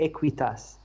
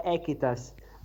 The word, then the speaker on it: Equitas: Em relação ao nome, é interessante porque a ser pronunciado, o nome é de origem latim, é de latim, e a ser pronunciado corretamente, devia ser Equitas. Só que nos Estados Unidos, pronto, as pessoas tendem a pronunciar as coisas como é mais fácil, e ficou Equitas.